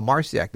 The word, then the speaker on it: Marciac: I said, no, Marciac. They're like, oh, no, you're saying it wrong. It's Marseille. Because Marseille is a big...